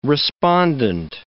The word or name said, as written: respondent